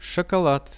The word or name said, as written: shokolad